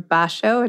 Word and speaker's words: Basho: Poem beginning with a line from Basho.